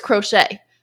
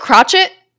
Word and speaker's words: crochet: I know it's crochet. I called it crotchet for far too long.